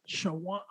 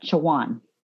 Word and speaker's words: Chowan: So we so there is actually a university that's spelled C H O W A N it's Shawan University. Everybody called it Shawan. Okay. It's Shawan.